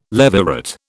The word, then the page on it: levirate: British English